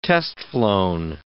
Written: test-flown

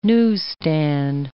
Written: newsstand

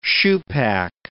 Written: shoepac

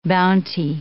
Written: bounty

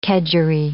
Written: kedgeree

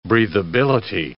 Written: breathability

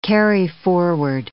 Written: carryforward

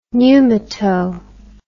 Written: pneumato

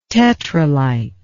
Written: tetralite

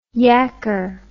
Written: yacker

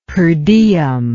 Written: per diem

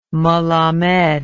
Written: melamed

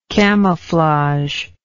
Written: camouflager

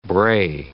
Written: brae

